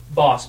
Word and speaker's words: Boss: Is it Bosch? Like CH or just Boss? – Boss, B-O-S-S.